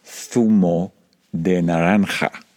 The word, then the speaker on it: zumo de naranja: Every time I order an orange juice at a bar in Madrid, it feels like a vocal contortion to produce the words zumo de naranja, because there is no thu sound in English.